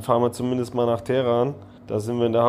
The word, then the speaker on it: Teheran: Teheran, richtig ausgesprochen,